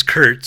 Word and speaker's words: Curt: But my name's Curt, C-U-R-T, but I spell it with a K, K-I-R-K-E-E-B, Kirkee B.